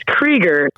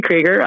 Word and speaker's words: Krieger: It's Krieger, Krieger, even though for like 15 years my husband let me say it wrong.